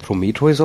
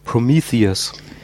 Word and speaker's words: Prometheus: Es sei denn, ihr wollt den deutschen Titel nennen, aber da wir hier englische Filme besprechen, der heißt ja nicht Prometheus auf Englisch, sondern Prometheus. So wird das ausgesprochen.